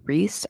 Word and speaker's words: Rhys: So, this legend is called Rhys at the Fairy Dance. – Okay. – And it's spelled R-H-Y-S.